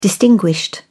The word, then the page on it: distinguished: British English